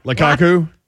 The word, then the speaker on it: Lukaku: Lukaku? – There we go.